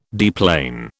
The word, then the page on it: deplane: British English